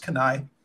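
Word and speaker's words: Keni-H: It's spelled K-E-N-I hyphen H. It's pronounced Kenai.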